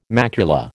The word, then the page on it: macula: American English